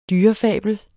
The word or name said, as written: dyrefabel